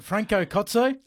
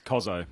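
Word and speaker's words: Franco Cozzo: That was on the back of last night because I didn't know much about, is it Franco Kotso? – Yes. – So we're talking about that. We're from WA, so we had a go. – Right. – Ben pronounced Kozo, which horrified most of our listeners.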